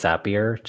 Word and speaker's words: Zapier: I believe it is Zapier just because they say it rhymes with happier.